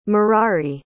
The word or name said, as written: merari